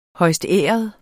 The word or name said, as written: højstæret